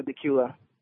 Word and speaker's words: Dacula: So, am I saying that right in Dacula, Georgia? – Dacula.